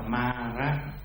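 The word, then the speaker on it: māra: M-A dấu ngang trên đầu R-A đọc là ma ra, tức là ma, là để chỉ cho các cái hàng xấu ác.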